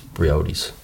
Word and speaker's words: Briody's: He was telling us to go to Briody's, and the way he pronounced it was Briody's.